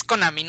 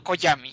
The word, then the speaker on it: Konami: Es Konami, no Koyami.